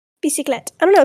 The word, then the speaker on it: bicyclette: bicyclette. I think I'm saying that right. Like bicycle. Mm. TTE, bicyclette. – TTE, so it's French.